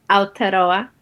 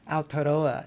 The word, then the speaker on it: Aotearoa: Aotearoa. – Aotearoa.